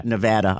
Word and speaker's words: Nevada: And the cops, Nevada, how do you say it? – Nevada.